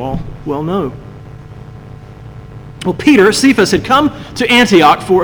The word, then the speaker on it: Cephas: You see, Paul in this letter, he refers to Peter as Cephas, or probably more appropriately pronounced Cephas, which is the name Paul most commonly uses in his letters.